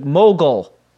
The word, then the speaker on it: mogul: Mogul is how you pronounce Mogul, as I was saying it last week. Mogul. Like Mo Howard and a seagull. Mogul.